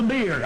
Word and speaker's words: bier: Now that's how it's pronounced, on the bier. I used to say on the bier, but it's on the bier.